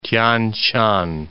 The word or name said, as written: Tian Shan